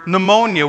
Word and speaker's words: pneumonia: So like pneumonia, why do you have to have the silent P at the beginning of the word pneumonia?